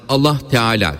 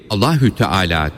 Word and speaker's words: Allahü Teala: Bunun için her zaman Allah Teala dememeli, Allahü Teala deyip H harfini belli etmeye çalışmalıdır.